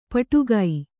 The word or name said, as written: ಪಟಗ